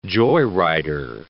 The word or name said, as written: joyrider